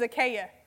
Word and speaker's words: Achaia: My name is Achaia. It's spelled different, but it's pronounced the same.